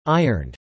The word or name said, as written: ironed